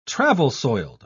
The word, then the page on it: travel-soiled: British English